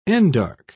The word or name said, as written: endarch